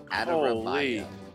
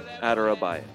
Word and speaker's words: Adarabioyo: Adarabioyo. – Tosin Adarabioyo. – There's a silent O in there.